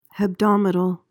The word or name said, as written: hebdomadal